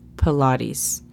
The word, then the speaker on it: Pilates: De nuevo, se escriben igual en inglés y en español, pero la pronunciación es un tan diferente. En inglés se dice Pilates. P E L A D E S.